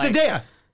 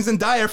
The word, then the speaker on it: Zendaya: It's Zendaya. – It's Zendaya. Sorry. – Sorry, listeners, tune into our Challenger review where I was corrected on how to pronounce the name by like three people. But it is Zendaya.